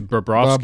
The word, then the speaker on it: Bobrovsky: Bob Broski. – Oh, good job. – Close. It's Bobrovsky.